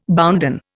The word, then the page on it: bounden: Indian